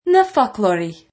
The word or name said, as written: focloir